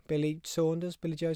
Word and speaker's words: Billy Joe: Canelo versus Billy Joe, is that how you say it? Billy Joe Saunders, yeah.